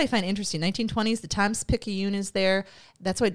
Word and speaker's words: Times-Picayune: So he also told me that this is pronounced Times-Picayune. So the Times-Picayune...